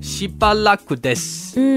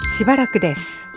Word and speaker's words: しばらくです: しばらくです。 – 好耐冇见。 – しばらくです。 – 我觉得头嗰三个字嘅读音咧，好似广东话嘅是巴拿。 – 系，定系我读得似咋？不如咧，我哋听多次正确嘅发音啊。 – しばらくです。